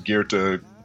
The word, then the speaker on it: Goethe: You know, I always say his name wrong. His name is Goethe. I think that's the philosopher's name. Goethe.